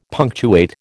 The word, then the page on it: punctuate: American English